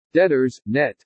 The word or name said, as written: DEBTORS NET